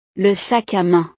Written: le sac a main

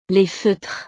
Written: les feutres

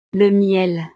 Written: le miel

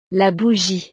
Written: la bougie

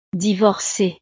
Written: divorce

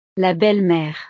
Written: la belle mere

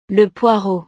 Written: le poireau

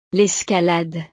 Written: l'escalade

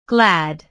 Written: glad

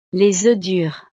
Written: les oeufs durs

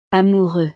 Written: amoureux

